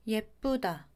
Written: 예쁘다

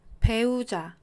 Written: 배우자

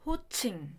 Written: 호칭

